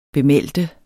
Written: bemeldte

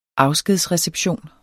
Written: afskedsreception

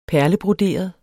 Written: perlebroderet

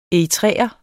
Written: eritreer

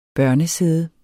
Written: børnesæde

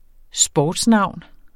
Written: sportsnavn

